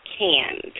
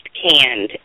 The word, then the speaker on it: Kand: Last name is pronounced Canned. So if anyone, if you see our Facebook page or our blog talk posts and it's spelled K-A-N-D, it's pronounced Canned.